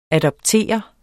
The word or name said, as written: adoptere